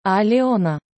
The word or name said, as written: alyona